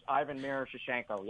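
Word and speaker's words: Ivan Miroshnichenko: How about, is it Ivan Mirshoshenko? Am I pronouncing his last name right? The Russian prospect? – Ivan Mirshoshenko. Yep, that's the kid, and I'm sure you're going to ask where these Russian players are going to fall.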